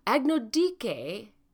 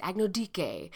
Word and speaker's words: Agnodice: Agnodice, I think I said it wrong, it's Dike, not Dice. I wanted to say Dice, and then I found a YouTube video that said it's Agnodice.